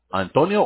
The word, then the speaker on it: Antonio: Antonio or Tony? – My legal name is Antonio. – Oh, you would spell that A-N-T-O-N-I-O.